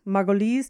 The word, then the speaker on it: Margolyes: Margolyes.